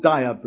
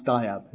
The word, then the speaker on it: Dagyab: So I did what my guru told me, and I wrote to the lama in Germany, who was called Dayab Rinpoche, which is spelled D-A-G-Y-A-B, Dagyab. It's pronounced Dayab.